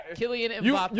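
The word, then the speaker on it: Kylian Mbappé: Mbappe? How do you say his name? He is not. – Killian Mbappe.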